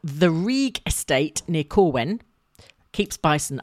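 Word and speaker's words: Rhug: As in R-E-E. – It's R-H-U-G, but you pronounce it Rieg.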